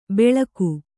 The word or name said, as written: ಬೆಳಕು